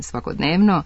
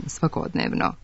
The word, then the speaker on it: svakodnevno: Stoga, umesto verovatno više spratnica, očigledno, prevashodno, radijator, svojevrstan, svakodnevno, treba reći verovatno više spratnica, očigledno, prevashodno, radijator, svojevrstan, svakodnevno. Akcenat će se na isti način prenositi i u stranim rečima koje se češće javljaju sa silaznim akcentom unutar reči.